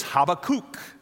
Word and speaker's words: Habakkuk: And by the way, most of us have learned to pronounce his name, Habakkuk. The literal pronunciation is Habakkuk. If you prefer to call him that, that's fine. That perhaps is a little more fun to pronounce than Habakkuk, but he is a man with a burden.